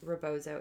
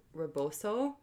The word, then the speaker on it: Rebozo: Well, she'll be mad because I pronounced it the very white way. Rebozo. It's actually like Reboso. – Mm. – I probably have still butchered that.